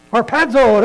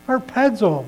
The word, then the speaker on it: Harpazo: What's the Greek word? Har, pad, one more syllable. Harpazo, there you go. Harpazo, we're going to be instantly taken up to be with the Lord.